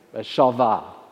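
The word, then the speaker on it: shalvah (שלווה): And the second word you probably haven't heard of so much, shalvah, or shalva. My Hebrew pronunciation is, anyone who knows it well will probably correct me on that, shalvah.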